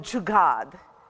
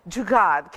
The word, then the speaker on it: jugaad: There is a word called jugaad. It's a Hindi word. Jugaad. Can you say it with me? Jugaad. J-U-G-A-A-D.